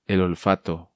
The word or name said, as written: El Olfato